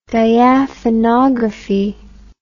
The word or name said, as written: diaphanography